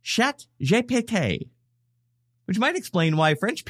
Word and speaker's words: pété: which is pronounced pété.